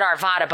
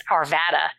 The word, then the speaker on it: Arvada: Well, she said Arvada, but if you live there, it's Arvada.